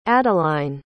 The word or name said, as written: adline